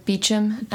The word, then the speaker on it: Beauchamp: Anyways, it's spelled like Beauchamp, whatever. – Beauchamp? – Beauchamp. – Or Beecham. It is pronounced in Outlander.